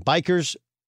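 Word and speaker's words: Bueckers: Bikers.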